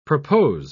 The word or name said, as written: propose